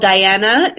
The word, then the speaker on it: Diana: It's spelled Diana, D-I-A-N-A, and then D-O-R-E-L-L.